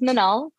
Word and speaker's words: Minal: And when I got to Salesforce, my manager would introduce me as Minal, but I would introduce myself as Minal. And it got to the point where she pulled me over and she's like, am I saying your name wrong? And I'm like, actually, you're saying it right. I'm the one pronouncing my name wrong.